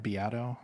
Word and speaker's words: Beato: Is that how you say his name? – Beato. – Beato. – Yeah.